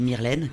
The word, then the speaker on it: Myrlène: Je m'appelle Myrlène, comme la lessive, mais ça s'écrit pas pareil.